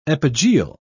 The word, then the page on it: epigeal: British English